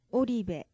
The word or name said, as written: oribe